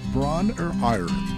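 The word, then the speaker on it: Bron-Yr-Aur: Bron or Iron is my understanding of the pronunciation.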